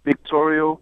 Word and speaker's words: Victorio: It's actually Victorio. – Victorio. That's a V.